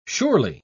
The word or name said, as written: surely